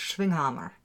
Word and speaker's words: Schwinghammer: Well, no, but see that the proper pronunciation is Schwinghammer. It's German.